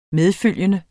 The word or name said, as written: medfølgende